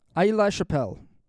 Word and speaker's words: Aix-la-Chapelle: The Treaty of Aix-la-Chapelle. I'm pretty sure the X is silent. Aix-la-Chapelle, 1748.